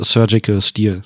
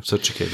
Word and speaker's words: Surgical Steel: Du meinst das 360 Sir Digital Steel, also wie auch man das aussprechen mag. – Ja, genau. Ja. Und das als Kette, Surgical Steel. – Surgical, ja, genau, danke.